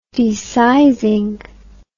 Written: desizing

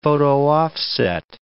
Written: photo-offset

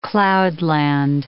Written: cloudland

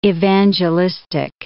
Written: evangelistic

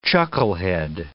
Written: chucklehead

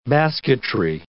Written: basketry

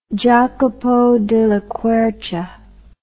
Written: Jacopo della Quercia

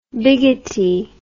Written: bigotty

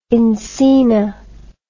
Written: encinal

